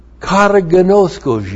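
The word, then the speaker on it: Kataginosko (καταγινώσκω): And therefore, it kataginoskos you. Damn. I hate it when it kataginoskos me. Kataginosko. K-A-T-A-G-I-N, long O, S-K, long O, kata gnosko.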